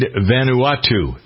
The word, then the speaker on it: Vanuatu: The name of the cyclone that destroyed Vanuatu, V-A-N-U-A-T-U.